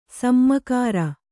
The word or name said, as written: ಸಮಗಾರ